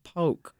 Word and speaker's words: poke: What is poke? P-O-K-E. – I'm glad to hear how it's pronounced because I've been calling it poke. – Our amazing producer, Cindy Hahn, told me that it was poke.